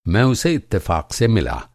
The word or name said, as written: इत्तफ़ाक़